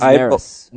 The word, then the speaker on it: Neris: Did I say that right? – I think it's Neris. – Neris. – Yeah, Neris.